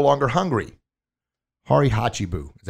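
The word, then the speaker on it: hara hachi bu: And this is, and I got my paper here, so I say it right. Harihachibu. Yeah, I said it. Yes, it's a Japanese word, harihachibu, which means essentially eat until you're 80% full.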